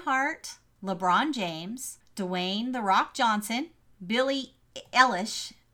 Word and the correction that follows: Eilish: Eilish, I always say her name wrong, and Ariana Grande.